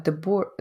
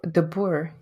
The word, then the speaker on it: deBoer: Uh, so it's De Boer, and um, and it is Dutch. So I'm not sure how you pronounce it there, but yes, very very close to that. De Boer, De Boer.